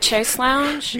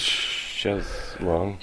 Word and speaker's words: chaise longue: Well, it's pronounced chaise longue. – Is it? – Yeah. – It's pronounced long. – I work at the American Printing House for the Blind, and that's the only way they'll let me say it. – Chaise longue? – Chaise Longue. – L-O-U-N-G-E. – Yeah, L-O-N-G-U-E. Long. – I thought that was lung, like tongue. – Well, it is sort of, but it does not pronounce that way in French.